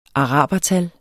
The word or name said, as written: arabertal